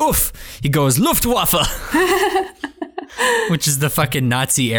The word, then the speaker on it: Luftwaffe: No, Luftwaffe. Luftwaffe is the German pronunciation, the aerial warfare branch of the combined German Wehrmacht military forces during World War II.